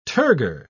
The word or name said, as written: turgor